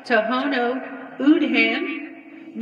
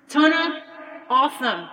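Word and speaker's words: Tohono O'odham: It looks like Tohono Udham, but it's pronounced Tona Otham.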